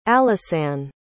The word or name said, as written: alisanne